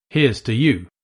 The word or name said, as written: heres to you